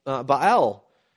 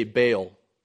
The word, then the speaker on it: Baal: Baal, or you say Baal, but that's not the proper way to pronounce it.